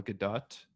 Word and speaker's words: Gadot: Henry Cavill as Superman Clark Kent, Amy Adams, Jesse Eisenberg, Diane Lane, Lawrence Fishburne, Jeremy Irons, the aforementioned Holly Hunter, Gal Gadot, that is how you pronounce it. – Is it really? – Yes, it is not Godot. Yeah, it is Gadot.